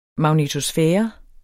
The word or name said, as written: magnetosfære